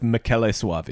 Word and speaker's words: Michele Soavi: When what I read about this one, it didn't turn me off per se, but Michele Soavi, is that how you say it? – I'd go with Michele Soavi. – Michele Soavi. – Just being the Italian that he is.